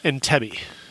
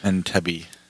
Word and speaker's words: Entebbe: Entebbe. – Entebbe.